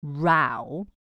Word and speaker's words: row: 比较道地的说法，把 argument 换成 row， have a row。 row 它的另外一个意思跟发音是 row，这个在端午节很常听到耶。 row your boat，那个划船的意思。